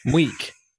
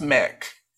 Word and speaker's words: Muick: It's M-U-I-C-K. – Meek. Mweek. Mweek. Mweek. Maybe it's Mweek. – I don't... – M-U-I-C-K would not be Mewich. – Oh, it's pronounced Mick. – Mick. Oh. Mick. Like that. It's like Mick.